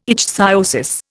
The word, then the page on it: ichthyosis: Indian